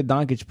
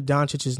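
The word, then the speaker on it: Dončić: Just an FYI, JC, Dallas Mavericks fans get very mad when you mispronounce Luka Doncic's name. It's not Doncic, it's Doncic. – Oh, I say Doncic, right? – Yeah.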